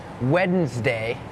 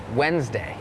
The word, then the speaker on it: Wednesday: โล่งออกเหรอลูกโล่งใช่ไหมเห็นแล้วถอนหายใจเฮือกว่าเริ่มเลยก่อนเริ่มรายการพูดถึงคำว่าอะไรน้องๆจำได้ไหมคะ Silent letters ใช่ไหมคะนั่นก็คือตัวอักษรที่ไม่ออกเสียงแต่อย่างคำนี้ค่ะเป็นคำหนึ่งที่มี silent letters Wednesday has that D W E D it's not wed Wednesday Wednesday D is silent